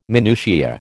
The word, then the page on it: minutia: American English